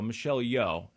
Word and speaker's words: Michelle Yeoh: Michelle Yeoh. And that's how you pronounce it. I looked it up on YouTube. – Sure. – So I'm not just guessing here.